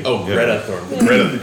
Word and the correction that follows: Thunberg: Greta Thunberg, yeah.